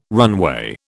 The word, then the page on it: runway: British English